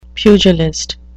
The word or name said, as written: pugilist